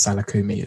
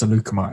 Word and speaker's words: Saleucami: I think I pronounced it wrong last week as Salakumi. It's Saleucami, apparently.